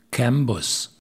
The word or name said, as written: Cambus